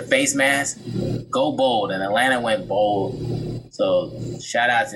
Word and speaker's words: Atlanta: So shout out to the Atlanta Atlanta. You don't pronounce the T.